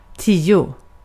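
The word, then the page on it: tio: IPA: [ˈtiːˌ.ʊ]; IPA: /ˈtiːˌɛ/; Swedish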